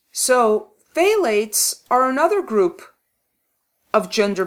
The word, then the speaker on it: phthalates: PH, which is silent. PHTHALATES. And the PH is silent, so it's phthalates.